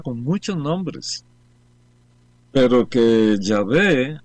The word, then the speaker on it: Yahvé: Pero luego se dieron cuenta, no, la mejor pronunciación de esto es Yahvé,